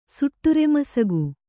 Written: ಸುಟ್ಟುರೆಮಸಗು

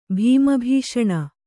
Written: ಭೀಮಭೀಷಣ

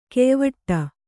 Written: ಘಾಯವಡೆ

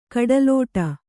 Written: ಕಡಲಾಡಿ

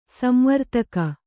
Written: ಸಂವರ್ತಕ

